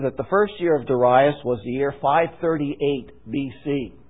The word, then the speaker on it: Darius: And this is what we read, Daniel chapter 9, verse 1, "In the first year of Darius," or Darius, depending on which syllable you put the accent,